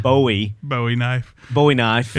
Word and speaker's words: Bowie: You said it right, and I'm proud. – Yeah, well, I did it just for him because it's been like nails on a chalkboard for him. I said Bowie knife.